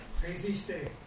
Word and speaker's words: résister: Now, it looks like register to you, but that's French. And that, pronounce that? – Résister. – You got it.